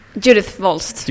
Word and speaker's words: Judith Wolst: Judith Wolst. – Judith Wolst. – It's difficult to pronounce in English.